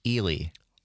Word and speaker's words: Ely: I will say, though, Phil in Minneapolis just called in and says it is pronounced Ely. – Oh, really? Ely. Well, see, immediately, that's another reason the people in Ely don't want me there is because I can't pronounce their town name.